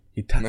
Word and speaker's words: Hitachi: Wie spricht man die denn Hitachi. aus? Hitachi.